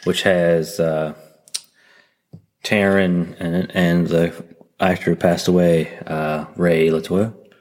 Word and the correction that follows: Liotta: Did I say his name? Right? He was on Cocaine Bear. – Ray Liotta. – Ray Liotta.